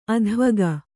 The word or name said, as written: ಅಧ್ವಗ